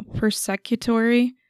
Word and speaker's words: persecutory: And then there's also, I'm going to say this wrong, I already know, persecutory. – Prosecutory? – Someone who... maybe. It says P-E-R-S-E-C-U-T-O-R-Y. – Oh, persecutory. – There you go. Persecutory.